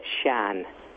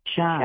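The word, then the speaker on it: Siân: And is her name pronounced Sian? Sian? – Yeah, she's a Welsh woman. It's Sian. – Sian. – Sian. Yeah, Sian. – Sian. S-I-A-N.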